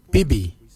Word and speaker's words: bibi: Your bill is spelled B-I-L-Y. Bill. The nipple, we say bibi. Bibi is spelled B-I-B-I. Bibi for your nipple.